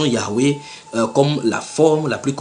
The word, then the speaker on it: Yahweh: Ils disent, voici ce qu'ils citent dans leur traduction, « Bien que nous inclinions à considérer la prononciation Yahweh comme la forme la plus correcte, nous avons retenu la forme Jéhovah.